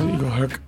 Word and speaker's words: IGLHRC: which we pronounce as IGLHRC. – And what does IGLHRC do? – Well, I'm so glad you asked, Steve.